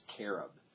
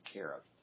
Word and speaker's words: cherub: In fact, even our word cherub, when we talk about cherubs, technically the Hebrew word cherub is carob. They don't say cherub. That's a kind of an English influence on pronunciation. So technically they pronounced him Carib.